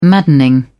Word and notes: maddening: British English

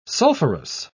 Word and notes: sulphurous: British English

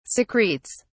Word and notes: secretes: British English

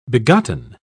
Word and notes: begotten: British English

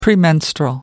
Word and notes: premenstrual: American English

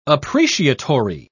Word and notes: appreciatory: British English

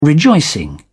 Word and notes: rejoicing: British English

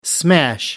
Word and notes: smash: American English

